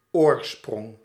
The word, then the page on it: oorsprong: /ˈoːrˌsprɔŋ/